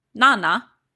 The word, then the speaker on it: Nanna: As Baldur's body was carried to his grand pyre, his wife, Nanna, according to the Icelandic pronunciation, come at me.